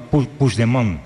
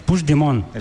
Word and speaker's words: Puigdemont: No, Puigdemont. – Puigdemont. Vale. Puigdemont. – Eso es. – Sí, lo dices como francés, así.